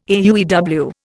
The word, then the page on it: AUEW: Indian